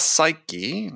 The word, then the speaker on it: Psyche: But uh, sales psyche, if I pronounce it, we pronounce the e on the end.